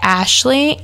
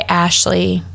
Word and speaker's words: Ashli: So my name is spelled A-S-H-L-I. So it's Inspired by Ashley with an I.